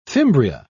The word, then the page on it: fimbria: British English